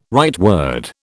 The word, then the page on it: rightward: British English